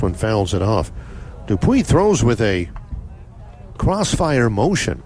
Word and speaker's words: Dupuy: In case you're wondering, Alex Dupuy is spelled D as in dog, D-U-P as in pine, U-Y. It's Dupuy.